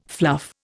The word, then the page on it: fluff: Indian